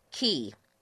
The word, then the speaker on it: ki: Ki, or perhaps you pronounce it differently, K-I. – I say ki, yeah.